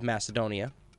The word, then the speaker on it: Macedonia: And it's something that's been very popular, because even though he was very unpopular during his own time outside of Macedonia, or Macedonia, we in the West have come to call it Macedonia, but I think the proper pronunciation is with a hard C.